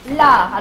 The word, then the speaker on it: Lares: les dieux lard. Alors pas le lard comme celui du cochon, hein. ça s'écrit L-A-R-E-S.